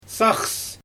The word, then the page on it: saxs: Xyzyl